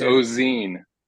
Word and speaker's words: Ozenne: Yeah, it's Ozenne.